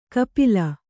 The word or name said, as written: ಕಪ್ಪಲಿ